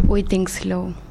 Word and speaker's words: Whittingslow: Whittingslow.